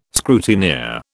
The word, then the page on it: scrutineer: British English